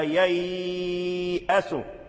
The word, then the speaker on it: ييأس: لا تمد على الألف لأن بعد الياء ألف مرسومة، هذه الألف المرسومة ثابتة في الخط محذوفة في اللفظ، لا تلفظ بها، فاللفظ بها لحن، هي ثابتة في المصحف، في الرسم، في رسم المصحف، لكن لا يلفظ بها، فتقول: لا ولا تيأس، ولا تيأس، إنه لا ييأس،